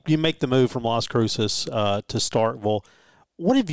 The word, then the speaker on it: Las Cruces: Hey, what, what's the right way to say it? – Las Cruces.